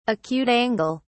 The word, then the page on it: acute angle: British English